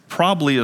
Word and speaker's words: probably: Probably is not a word. Probably is. There are two B's in probably. Some people say it without any B's, and it's probably.